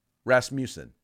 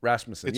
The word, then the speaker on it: Rasmussen: Rasmussen. I've heard it pronounced both ways. – Okay. See, I've never heard it pronounced anything other than Rasmussen. And you said Rasmussen. I'm like, that's interesting. So.